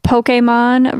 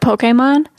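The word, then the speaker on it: Pokémon: Of Pokemon? – Of Pokemon. There's an accent on that E.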